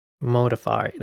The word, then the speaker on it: Modafferi: She last lost to Roxanne Modarefi. Can't even say her name. Modafferi. There you go.